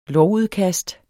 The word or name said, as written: lovudkast